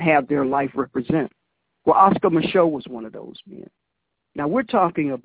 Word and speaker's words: Oscar Micheaux: Now, his name is spelled O-S-C-A-R, and he has a French last name, M-I-C-H-E-A-U-X, Oscar Micheaux.